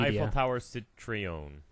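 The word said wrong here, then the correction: Citroën: I think it's Citron. – Citrion. – Citron. – There we go.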